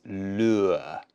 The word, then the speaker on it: lure: Difficult pronunciation. So it's lure, lure. Really strange one. Your mouth goes in and then out.